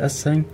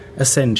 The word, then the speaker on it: Assange: Aber der WikiLeaks-Founder heißt übrigens Julian Assange, oder wie man es ausspricht, Assange. Assange, ja, spricht man es aus.